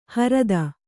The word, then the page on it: ಹರ್ದು: hardu